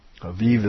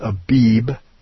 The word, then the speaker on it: Aviv: Aviv is the biblical. I think it's pronounced Abib in the Bible, but it's Aviv.